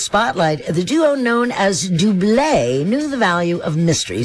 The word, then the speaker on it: Double: And yes, it is pronounced Dublé. Even though it's spelled D-O-U-B-L-E, which in the U.S.